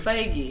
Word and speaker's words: Feige: That's how you say that guy's name, by the way. – Feige. – Yes, it's Feige.